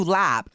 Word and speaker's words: larb: Some of the best shit, if you like larb on the menu, lap is, you know, actually how it's pronounced.